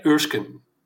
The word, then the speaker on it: Erskine: De Donald Glover remake reboot van Mr. en Mrs. Smith met uh, tegenspeelster Maya Erskine Ursken zeg ik dat goed? – Erskine, ja, ik had hem nog even gegoogeld.